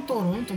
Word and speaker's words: Toronto: Tipo, o jeito que as pessoas aqui da cidade, né, o canadense médio, principalmente quem mora em Toronto mesmo, se refere à cidade, em termos de pronúncia e tal, é Toronto. Então so, esse esse segundo T ali praticamente nem existe.